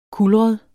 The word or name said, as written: kulret